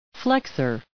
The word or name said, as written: flexor